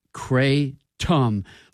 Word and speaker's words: kratom: By the way, Stephen Sussman just chimed in with the pronunciation, which is Kratom. Like Randall said, the emphasis is on K-R-A-Y.